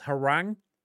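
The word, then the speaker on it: Hwoarang: Like I'm always and I always mispronounce it. It's Harang.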